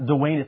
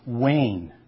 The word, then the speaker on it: Wayne: People say, Dwayne, no, not Dwayne, it's Wayne. One time I worked with a guy from West Virginia, and he would say, Wayne. I said, no, no. It's only one syllable, Wayne, okay?